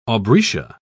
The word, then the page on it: aubrietia: British English